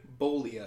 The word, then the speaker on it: Bollea: Terry Bollea. At least I think that's how you pronounce it. – Terry Bollea? How yeah. – do you spell that? – B-O-L-L-E-A. – It's like Ebola without the E. – Terry, T-E-R-R-Y? – Yeah.